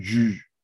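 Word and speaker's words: Llull: Bu arada o İspanyolca Cüj, Cüj diye okunuyormuş.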